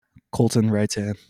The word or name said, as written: Coleton Reitan